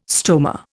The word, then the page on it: stoma: Indian